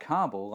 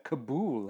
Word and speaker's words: Kabul: I pronounced Kabul. not Kabul, like we do over here, but Kabul, like they do over there.